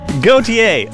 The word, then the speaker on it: Gauthier: Gauthier.